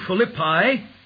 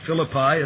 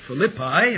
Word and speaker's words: Philippi: Verse 11 of Acts 16 tells us of Paul and his companion entering into Philippi, and that's the proper pronunciation, as I reminded you in the adult class, not Philippi, as I've said for years, but Philippi, a city of Macedonia.